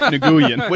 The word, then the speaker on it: Nguyen: Which is pronounced Nguyen, or as close to that as you can get without practice. It's actually a sound that is not found in English, but Nguyen is as close as you are getting going to get with standard English phonetics.